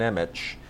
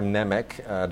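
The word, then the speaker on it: Němec: Codename Ruby is a film from Facets that, if you are a fan of Jan Nemec, or Nemec, depending on how you want to pronounce it, who is one of the great Czech New Wave directors, fascinating life to this guy.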